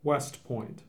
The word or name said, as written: West Point